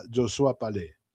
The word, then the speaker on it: Josua Palais: Si buscás ahí en el YouTube, para que, si no sabes francés, pones ahí Josías Palais y te va a dar la pronunciación correcta, Josua Palais.